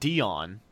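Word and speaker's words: Deone: Now, it's spelled Deion, but it's pronounced Deion.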